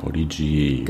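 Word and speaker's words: Podigee: wie wird das ausgesprochen? Podigy, ne? – Podigy.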